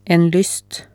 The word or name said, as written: en lyst